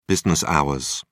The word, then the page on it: business hours: British English